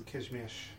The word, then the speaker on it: Kazimierz: It's Kazimierz, but it's okay, keep going.